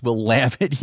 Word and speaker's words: Willamette: Yeah, thank you for coaching me up on how to pronounce Willamette University.